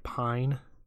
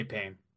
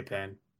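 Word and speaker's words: Paine: Also, isn't it pronounced pine? – No, they say pain. – Oh, I thought it was a pine. – They say pain.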